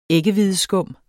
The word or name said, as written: æggehvideskum